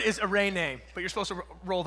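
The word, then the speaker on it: eirene (εἰρήνη): And so in the New Testament, the word that's used for peace is this Greek word that's pronounced eirene. I suck at rolling my R's. So it's eirene, but you roll the R.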